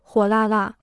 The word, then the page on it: 火辣辣: huǒ là là